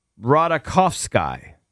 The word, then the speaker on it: Ratajkowski: It's pronounced Ratajkowski.